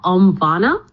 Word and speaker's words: Omvana: And then the other meditation app is called Omvana, which is spelled O-M-V-A-N-A.